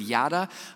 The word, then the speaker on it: yada: The Hebrew word is yada. It's how I'm going to pronounce it for us to understand today. It's kind of like, you know the phrase, like yada, yada, yada, kind of like that.